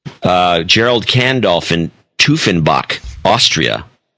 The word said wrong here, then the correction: Teufenbach: Tufenbach, I think it's pronounced right. T-E-U-F-E-N-B-A-C-H. – Teufenbach. – You think it's Toy? – Teufenbach. – Teufenbach? Okay. – Teufenbach. – Right. 50. It's Gerald Kandolf. Marcin, this isn't... Okay, Marcin Bekiza, B-E-K-I-E-S-Z.